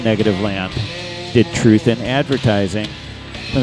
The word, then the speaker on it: Negativland: And we started off with Negative Land, or Negativ Land, if you want to be technical, because they're named after the German group. And that's how you say it, if you're pretentious.